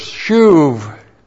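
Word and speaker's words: Shuv (שוב): Shuv. S-H long U-B, shuv.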